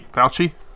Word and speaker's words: Fauci: However, first thing I wanted to bring up, I know it's not a question that we've fed you beforehand, but how do you pronounce his name? Fauci? Is it Fauci? Dr. Fauci? Dr. Fauci? – Fauci. – He's a Fauci.